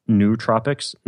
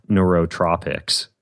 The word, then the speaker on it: nootropics: Parentheses, Ben, it's pronounced nootropics, not neurotropics.